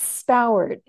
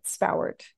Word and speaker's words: Spowart: Yeah, it's Spoward. It's a little Spoward. Unusual. Apparently it's Scottish in origin, but Spoward.